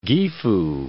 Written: Gifu

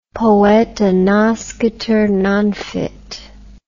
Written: poeta nascitur non fit

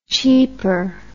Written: cheeper

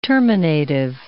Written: terminative

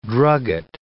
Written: drugget

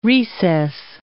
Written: recess

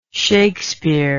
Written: Shakspere

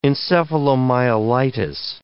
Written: encephalomyelitis

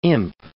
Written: imp

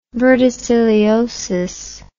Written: verticilliosis

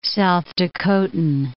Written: South Dakotan

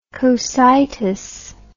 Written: Cocytus

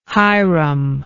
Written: hiram

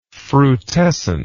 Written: frutescence

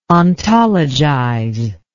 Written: ontologize

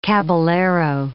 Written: caballero

